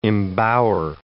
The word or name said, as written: embower